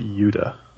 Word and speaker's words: Yuta: Also tonight, Sammy Guevara will take on Wheeler Utah? Whoever the fuck that is. – It's not pronounced like the state, Sal.